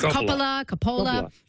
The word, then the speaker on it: Coppola: Coppola.